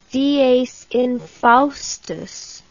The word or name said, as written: dies infaustus